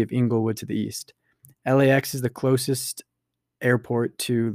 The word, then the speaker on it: LAX: Los Angeles International Airport, commonly referred as LAX, with each of its letters pronounced individually, is the primary international airport serving Los Angeles and its surrounding metropolitan area.